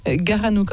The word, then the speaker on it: Garanou: On peut retrouver plein d'infos en tapotant Le Florida dans un moteur de recherche et puis on peut vous écouter Garanou aussi, Garanou en un seul mot et sans S à la fin et sans E entre le gar et le A. Garanou, comme ça se prononce, Garanou raconte Nougaro, donc avec vous, Serge Mathéon.